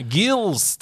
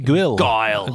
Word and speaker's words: Guile: Jeg hadde ikke Jeg hadde ikke eh, dette er Street Fighter 2, Gills team, eller theme, eller Guil. Guile.